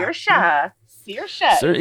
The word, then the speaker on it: Saoirse: The movie that introduced us all to, how do you say that? – Yeah. Saoirse. – Yeah. No. You're joking with me. None of those letters are in there.